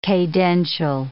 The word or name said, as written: cadential